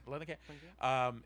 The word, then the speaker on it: Langenkamp: Langenkamp.